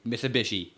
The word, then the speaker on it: Mitsubishi: It's one of the Mitsubishis. – Mitsubishis. That's over $40,000. – Is it Bushi? – Mitsubishi Lancer or something. – Mitsubishi. – Mitsubishi. Not Bushi.